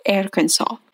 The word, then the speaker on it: Arkansas: Обратите внимание, Арканзас на английском читается как Arkansas.